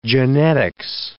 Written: genetics

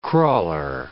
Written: crawler

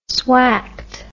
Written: swacked

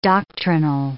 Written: doctrinal

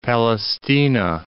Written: Palaestina

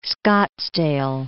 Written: Scottsdale

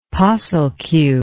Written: posslq

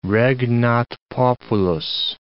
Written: regnat populus

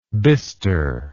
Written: bistred

